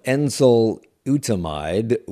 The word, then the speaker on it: enzalutamide: And I know that you were involved in a study of a treatment using a combination, I'm going to mispronounce this, of enzalutamide with androgen deprivation therapy. You say it correctly for me. – Enzalutamide. – Okay, I was close.